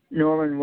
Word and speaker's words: Norman: Norman Wells.